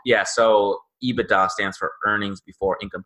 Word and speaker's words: EBITDA: It's EBITDA.